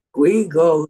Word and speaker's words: Guigo: It's Guigo the Second, yes.